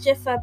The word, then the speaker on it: Dzifa: My name is Dzifa, but it's spelt with a DZ, so the DZ is like a J, so it's DZIFAX.